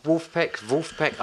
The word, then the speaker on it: Vulfpeck: He went, it's Vulfpeck. I said, spelt V-U-L-F-P-E-C-K. – Yeah. Vulfpeck.